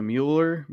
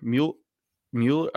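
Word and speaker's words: Müller: I think the general pronunciation is Mueller, but if you want to be like real... If you want to be real about it, it's Mueller. – Okay.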